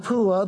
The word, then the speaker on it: Puah: I'm glad Puah wasn't pronounced P-U, that would be kind of odd, but the son of Puah,